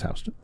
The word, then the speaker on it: Houston: It looks like Houston. It's pronounced Houston.